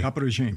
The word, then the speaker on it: caporegime: Right, Michael, your Twitter bio, and forgive me for my terrible pronunciation of this, says former Kappa regime. – You got it. Yeah. – Did I? – Kappa regime, yeah.